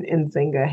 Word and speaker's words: Nzinga: And then you can just find me on Facebook and LinkedIn with my whole name, Nzinga Harrison. – Very cool. And Nzinga is spelled N-Z-I-N-G-A.